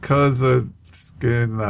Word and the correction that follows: Kunaszyk: I don't know, something. But congratulations to Jordan. – That doesn't sound right. – It's Kunaszyk. Take a shot?